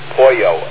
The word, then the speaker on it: Horyu: I guess it's pronounced Hoyo, H-O-R-Y-U, too.